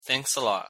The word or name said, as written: thanks a lot